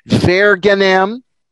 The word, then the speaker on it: Verginem: Verginem, you pronounce the V as a V.